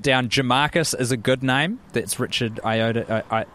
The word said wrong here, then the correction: Ayoade: How do you say it? – Ayoade.